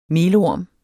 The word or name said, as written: melorm